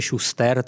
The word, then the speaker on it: Schuster: jako psáno německy, ale italsky se to čte Schuster, tak budu dodržovat tu italskou výslovnost, že jim žehná veřejně na Piazza del Duomo v Miláně.